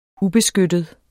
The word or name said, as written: ubeskyttet